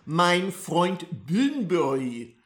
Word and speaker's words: Mein Freund Bunbury: Mein Freund Bunbury is how the Germans would pronounce it.